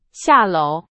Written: 下楼

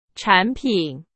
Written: 产品

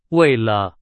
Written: 为了

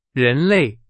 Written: 人类

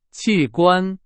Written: 器官

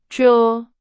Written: 着

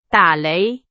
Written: 打雷

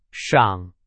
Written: 赏

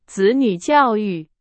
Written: 子女

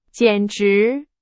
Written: 简直

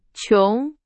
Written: 穷

